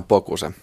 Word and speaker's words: Bocuse: Miten äh, Bokuse teillä päin muuten lausutaan? Se on suomalaiset ihan Bokuse.